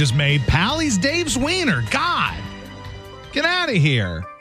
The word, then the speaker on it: Zwiener: Is it Zwiener or Zwiener? – It's Zwiener, but everybody pronounces it as Wiener, too, so you know how that goes.